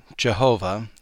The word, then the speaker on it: Jehovah: Let's consider the predominant name for God, the Father, as revealed in the Old and New Testaments, pronounced either Jehovah or Yahweh.